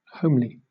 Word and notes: homely: British English

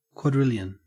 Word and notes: quadrillion: Australian English

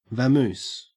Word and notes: vamoose: Australian English

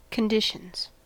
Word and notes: conditions: American English

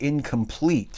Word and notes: incomplete: American English

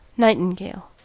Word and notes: nightingale: American English

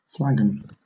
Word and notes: flagon: British English

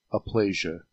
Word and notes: aplasia: American English

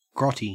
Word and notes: grotty: Australian English

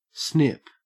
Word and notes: snip: Australian English

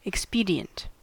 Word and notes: expedient: American English

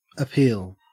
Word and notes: appeal: Australian English

